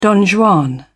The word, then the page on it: don juan: British English